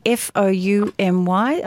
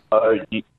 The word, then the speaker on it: Fourny: So Veuve Fournier, Fourmy, I think that's how we spell it, yeah? – Fourny. – Oh, R-N-Y, sorry.